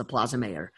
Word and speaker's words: Mayor: It's M-A-Y-O-R, like mayor of a city. That's how it's pronounced, the Plaza Mayor.